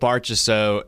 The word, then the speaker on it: Marchessault: Marchessault.